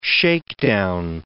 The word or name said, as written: shakedown